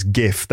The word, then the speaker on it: GIF: So it's the G should be pronounced with a G sound because of graphics. So it's GIF.